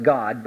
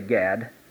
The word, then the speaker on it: Gad: And the prophet Gad, technically it's pronounced God, but we'll say Gad.